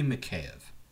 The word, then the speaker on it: Mikheyev: It's not an I. It's not a Y. There's an E before the Y. It's clearly Mikheyev.